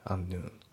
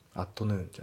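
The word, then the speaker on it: at noon: であと発音ですけど、アットの T がね抜けますね。アンヌーン、アットヌーンじゃなくてアンヌーンだね。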